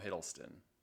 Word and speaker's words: Hiddleston: With Tilda Swinton and Tom Hiddleston. Hiddleston. I always say his name wrong. – I don't know. What's his name? Loki. – Tom Hiddleston.